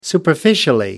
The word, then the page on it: superficially: British English